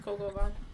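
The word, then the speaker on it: coq au vin: I'm going to say it wrong. It's coca bean. – Coco Van. – What? That is not how it's spelled. – No, not at all. – Coco Van. You can thank the French.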